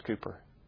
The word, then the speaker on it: Cowper: Now, we sang this hymn by William Cowper, spelled Cowper, it's pronounced Cooper,